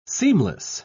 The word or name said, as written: seamless